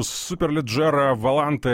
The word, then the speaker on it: Superleggera Volante: Надеюсь, ты просто еще и послушал, как я произношу Superleggera Volante. Это достаточно по-итальянски? – Да, достаточно.